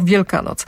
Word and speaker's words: Wielkanoc: Według profesora jest to zrost, który powstał z dwóch słów posiadających swój odrębny akcent. – Tak jak biały stok, krasny staw. Z drugich członów tych połączeń wyrazowych akcent znikł, a w pierwszym wyrazie akcent przesunął się o jedną sylabę i z połączenia wyrazowego biały stok zrobił się biały stok, a z połączenia wielka noc zrobiła się wielkanoc.